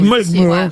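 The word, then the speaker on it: Mullaghmore: Une bombe télécommandée de 23 kilos a été placée près du moteur de l'embarcation dans une baie irlandaise à Mulgmu. Je sais pas si je prononce bien. – Non, c'est très bien prononcé. – Mulgmu.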